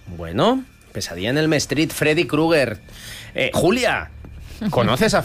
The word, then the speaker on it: Elm Street: Que no se dice así. – ¿Y cómo se dice? – Elm Street. – Ah, bueno, es que yo lo he españolizado, Elm Street. – Es que se escribe igual, ¿eh?